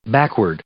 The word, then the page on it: backward: adverb